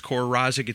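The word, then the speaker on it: Kore Rozzik: The band is Tor Rozic. It's spelled K-O-R-E. Second word, R-O-Z-Z-I-K.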